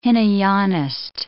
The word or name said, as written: Hinayanist